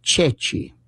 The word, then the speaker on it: ceci: One of them was that during the war, they would sometimes hold up a chickpea, and it's pronounced Ceci, C-E-C-I. But French could not pronounce that.